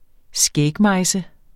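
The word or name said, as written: skægmejse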